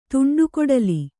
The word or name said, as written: ತುಂಡುಕೊಡಲಿ